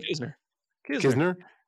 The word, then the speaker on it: Knizner: You know, Ali Marmol talked about, oh, we pitcher familiarity, like our guys, they know, they feel more comfortable with, I can't even pronounce his last name, is Knizner? How you pronounce it? What? – Kisner. – Just Kisner.